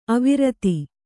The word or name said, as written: ಅವಿರೋಧ